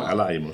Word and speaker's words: Alaimo: Alaimo, sì.